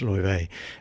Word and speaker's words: Laufey: It's Her name is actually spelt L A U F E Y, but it's pronounced Loive. That's my bad way of mutilating Icelandic.